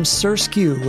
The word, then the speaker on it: SRSQ: Up next is a brand new song from Sersq, which is spelled S-R-S-Q.